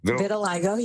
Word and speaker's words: vitiligo: Vitiligo, yep.